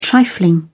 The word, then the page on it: trifling: British English